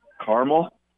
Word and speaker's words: caramel: Hey, how do you pronounce the word C-A-R-A-M-E-L? – Carmel? – There we go. Camel? Thank you. No, you got it. You nailed it.